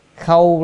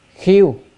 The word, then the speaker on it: khâu: Rồi khâu lăng khanh khảm, chữ khâu á còn có một đọc một âm khác là khiêu à, đôi khi đọc là tỳ kheo á, nhưng mà chữ này còn đọc kheo nữa,